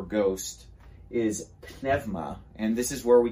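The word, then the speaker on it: pneuma (πνεῦμα): And pneuma here, pneuma, is the way you pronounce it in modern Greek, ek pneumatosistin agiou.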